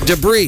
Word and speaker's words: debris: This is an interesting one, only because if you watch F1, because the guys, the announcers, are all English, so we would call it debris here. But when they're talking about stuff on the track, it's debris on the track. But here it's like debris. So are they mispronouncing it? As a Canadian, I'm saying, yeah, they sure are. They're at the Canadian Grand Prix. It's debris.